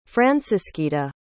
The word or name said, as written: francisquita